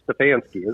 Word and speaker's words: Stefanski: I said that name wrong. Stefanski. Is that it? I always trip Stefanski? – Over that. – I think it's Stefanski, isn't it? – Stefanski? There, I said it right that time.